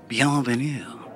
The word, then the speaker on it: Bienvenue: Bienvenue, she said, with that perfect back-of-the-throat pronunciation which most Americans mangled.